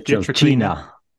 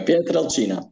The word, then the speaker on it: Pietrelcina: Pietrelcina. Yep. – There you go. Pietrelcina.